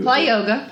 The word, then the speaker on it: ply yoga: I mean, and am I pronouncing it right or how do you... – Ply yoga. You're right.